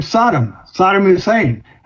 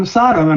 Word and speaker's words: Saddam Hussein: And I remember saying once, well, Mr. President, I think you pronounce it Saddam Hussein. And he just turned around and walked away. He never changed calling him Sodom.